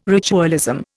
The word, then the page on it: ritualism: Indian